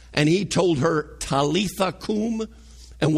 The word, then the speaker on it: koum: In the Aramaic, there's a word there that says kum, K-O-U-M. We pronounce it kum.